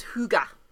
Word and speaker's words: hygge: The word is hygge. It's spelled H-Y-G-G-E, and it translates to coziness, and it's like a special...